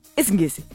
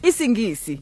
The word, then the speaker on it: Isingisi: This one is called Isingisi. – Isingisi. – Because apparently the way I said it earlier was wrong when I was like Isingisi.